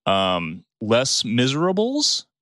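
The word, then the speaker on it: Les Misérables: Liam Neeson, to this point, his big ones were Schindler's List, Rob Roy, Les Miserables. Is that? – I think you're saying that right. That sounds right. That's right.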